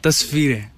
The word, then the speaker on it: Tasveere: I like, I'm probably pronouncing it really badly, but I like Tasveeria. – Tasveere. – Tasveere.